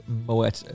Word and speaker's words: Moët: And a beautiful pronunciation of Moët as well, which is the correct pronunciation.